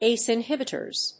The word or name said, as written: ace inhibitors